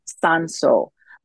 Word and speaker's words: Sanso: And they're fully American, and they naturally pronounce it Sanso, like, which I think is sweet.